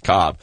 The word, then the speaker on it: Kolb: their redheaded Kevin Cobb, spelled K-O-L-B, pronounced Cobb, is the guy that led them to trade the face of their franchise for the last decade plus, Donovan McNabb.